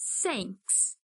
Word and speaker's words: thanks: Dessa vez, vamos pronunciar com S e colocar a língua entre os dentes, assim. Diga thanks.